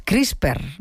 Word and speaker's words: CRISPR: Todo lo que tiene que ver con esto de las CRISPR, no sé si lo digo bien, ¿no? – Sí.